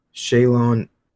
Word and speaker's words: Shaolin: In Mortal Kombat, Shaolin, that's how you say it.